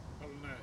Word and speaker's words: Ballina: Your wife Sarah is from Ballina. Is that how you say it? – Ballina, yeah.